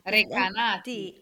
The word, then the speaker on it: Recanati: Recanati. – Recanati.